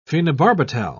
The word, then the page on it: phenobarbital: British English